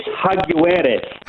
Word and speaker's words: Jaguares: It's Jaguares.